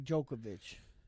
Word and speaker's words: Djokovic: Novak Djokovic.